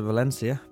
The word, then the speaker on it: Valencia: I've been to Valencia, as the locals and myself pronounce it.